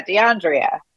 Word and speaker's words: DeAndrea: It's an Andrea, but it's spelled DeAndrea. And so it's fine. I think, again, that's also something cultural. My middle name is Andrea, and spelled Andrea. And so I naturally say DeAndrea.